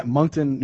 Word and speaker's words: Moncton: Moncton, New Brunswick, Moncton.